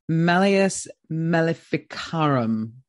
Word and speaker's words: Malleus Maleficarum: Malleus Maleficarum. How do you? – That's similar to how I've heard it pronounced. I think we're going to roll with that.